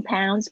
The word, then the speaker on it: pounds: 注意一下你们的 pounds，不要念成胖子，pounds 它是 ou 的声音，ou 的声音特别注意一下。啊，Carmen 在线的话，注意一下，我有给你改那个 counter 哦，不要念成 counter，counter。 OK，per package，per 不要念成 per，per 就是每一个 package。 The limit is fifteen pounds per package.